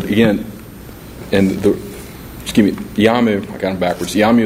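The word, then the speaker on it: Iyamu: And I believe it's pronounced YAMU. It's I-Y-A-M-U, which is 2006 Merit System Protection Board, Lexus 710.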